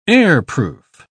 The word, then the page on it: airproof: British English